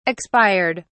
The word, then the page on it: expired: British English